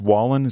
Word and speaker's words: Wallen: And the last name pronounced Wallen. Is that correct? – Yes. Thank you.